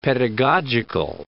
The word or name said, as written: pedagogic